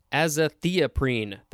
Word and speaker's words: azathioprine: Next, we have azathioprine, which we just mentioned in our example from the A-Z-A prefix. Azathioprine, that's how you pronounce it. A-Z-A-T-H-I-O-P-R-I-N-E.